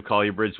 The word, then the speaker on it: colubrid: I was looking at a whole bunch of colubrids, which I talked to Dr. Warren Booth, or he sent me an email. He says, I do pronounce it correctly. It is colubrid.